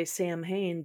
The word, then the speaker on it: Samhain: Really, I mean, it makes sense that they would say Samhain because...